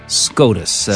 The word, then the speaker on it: SCOTUS: Un bel acronimo, che pronunciamo questa volta. SCOTUS.